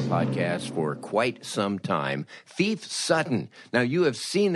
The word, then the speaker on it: Phoef: Thief Sutton, and of course he pronounces his name Thief and spells it P-H-O-E-F, is really a Renaissance man.